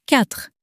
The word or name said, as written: quatre